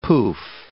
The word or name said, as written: poof